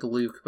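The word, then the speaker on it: Luc: Ooh, what about I'm gonna just pronounce it like Luke, but it's L-U-C.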